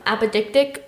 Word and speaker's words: apodictic: It's A-P-O-D-I-C-T-I-C. You would think it was apodictic, but it's apodictic.